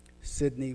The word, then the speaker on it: Sidney: Sidney with an I.